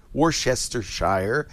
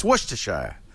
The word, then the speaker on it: Worcestershire: It's not Worcestershire. It's Worcestershire. Or Worcester.